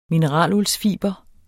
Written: mineraluldsfiber

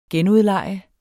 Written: genudleje

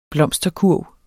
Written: blomsterkurv

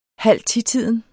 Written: halvtitiden

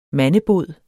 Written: mandebod

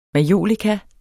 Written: majolika

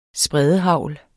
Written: spredehagl

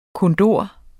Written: kondor

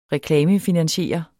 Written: reklamefinansiere

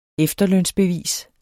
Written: efterlønsbevis

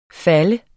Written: falde

